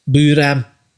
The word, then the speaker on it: BLAME!: If you're into science fiction, you can't beat Buram. – What is it called? – Buram. – Spell it for me. – BLAME! Exclamation mark. – Okay. Blame.